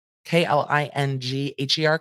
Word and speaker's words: Klingher: Baldwin III, Rust Movie Productions LLC, Eldorado Pictures, Ryan Donnell Smith, Langley Allen Cheney, Thomasville Pictures LLC, Nathan Kling, Klingher, K L I N G H E R, Klingher, um, Ryan Winstrin, Winstrine, Winstein, Winterstein.